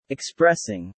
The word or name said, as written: expressing